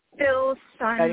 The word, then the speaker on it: Filsan: Filsan, like F-I-L, sun, like Philip and the sun together.